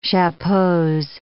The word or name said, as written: chapeaux